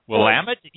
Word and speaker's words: Willamette: Not to interrupt you, Portia, but Willamette. You pronounce it. I've always heard, and I've always said Willamette, but it's Willamette.